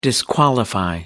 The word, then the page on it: disqualify: American English